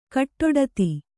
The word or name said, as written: ಗಡ್ಡಾದ